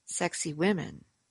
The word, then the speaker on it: Sacsahuaman: Peruvian traditions give the name Sacsahuaman, Sacsahuaman, Sacsahuaman, to that mount that was once called Zion. As you pronounce Sacsahuaman, as some tour guides instruct you, sexy women, you may never comprehend that it is the Egyptian deity Amen, whose name was given to this sacred hill.